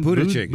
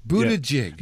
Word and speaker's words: Buttigieg: Buttigieg. – Buttigieg? – Buttigieg.